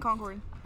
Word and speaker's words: Concord: Bueno, entonces después de tus dos años ahí en Concord, lo digo bien, Concord, sí, Concord.